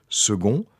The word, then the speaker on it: second: Ainsi, on disait non pas « Claude » mais « Glaude », tout en l'écrivant avec un C, à la manière de « second », mot dans lequel aujourd'hui le C reste toujours prononcé « G ».